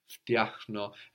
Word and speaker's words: φτιάχνω: So this is an interesting sound to make in Greek, like φτάνω, I arrive, φτιάχνω. And then we have the χ also, but at the end of the syllable, φτιάχνω, I fix, φτιάχνω.